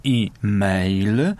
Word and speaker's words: e-mail: Przeszedł, bo początkowo, pierwotnie przypomnę, zapisywany był e, kreseczka, mail, bardzo często wymawiany oryginalnie i mail, jako oczywiście skrót, który powstał na gruncie języka angielskiego od wyrażenia electronic mail, dosłownie poczta elektroniczna. Potem oczywiście pojawiła się też wymowa doliterowa, bo tak to już jest, że nie każdy pamięta i nie każdy zna angielski, a więc pojawiła się wymowa e-mail, a nie e-mail.